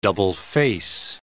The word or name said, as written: double-face